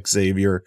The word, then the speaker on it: Xavier: I know that people hate that one in Cincinnati when you say it that way, but that is actually his pronunciation, is Xavier Suafilo.